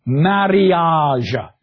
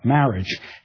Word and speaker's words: marriage: There are, as in Old English, no silent letters in Chaucer's language. You pronounce everything. Mariage, not marriage.